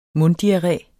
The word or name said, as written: munddiarré